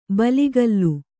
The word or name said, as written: ಬಲಿಗಲ್ಲು